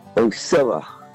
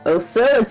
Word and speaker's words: Ocilla: Well, since you're from Macon, can you tell us, how do you say where Dave Prater's from? – Ocilla, Georgia. – Ocilla, Georgia. Thank you.